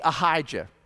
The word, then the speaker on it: Ahijah: King Ahijah. Rhymes with Elijah.